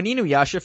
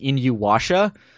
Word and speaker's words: Inuyasha: I mean, when Inuyasha first aired on YTV, they pronounced it Inuwasha.